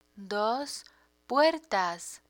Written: dos puertas

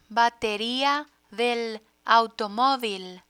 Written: bateria del automovil

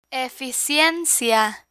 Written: eficiencia

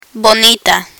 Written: bonita

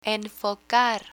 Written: enfocar

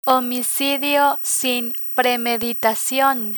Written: homicidio sin premeditacion

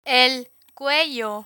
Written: el cuello

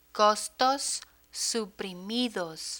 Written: costos suprimidos

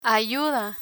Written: ayuda

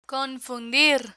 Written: confundir

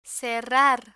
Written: cerrar

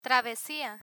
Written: travesia

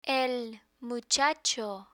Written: el muchacho

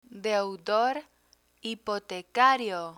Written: deudor hipotecario

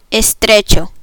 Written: estrecho

